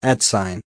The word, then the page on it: at sign: American English